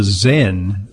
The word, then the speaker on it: Xen: It's called the Zen. It's spelled X-E-N by Nuvana, which is a vagal nerve stimulation device for consumers.